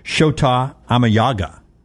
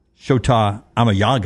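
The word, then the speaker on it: Shota Imanaga: Shota Amayaga. Shota Amayaga. – That's the actual pronunciation.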